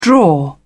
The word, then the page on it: draw: British English